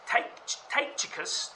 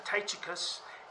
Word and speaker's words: Tychicus: Tychicus, it's hard to say for me, three syllables there, Tychicus and Onesimus.